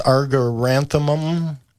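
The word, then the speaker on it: Argyranthemum: Boy, that one's a tough one for people to pronounce. Argaranthemum, I believe, is how it's pronounced.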